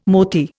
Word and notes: mothy: Indian

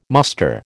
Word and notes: muster: American English

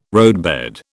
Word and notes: roadbed: British English

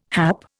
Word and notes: hap: Indian

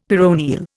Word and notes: peroneal: Indian

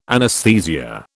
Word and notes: anaesthesia: British English

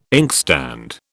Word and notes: inkstand: British English

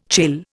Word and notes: chill: Indian

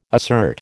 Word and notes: assert: American English

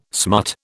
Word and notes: smut: British English

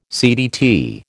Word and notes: CDT: American English

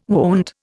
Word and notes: wont: Indian

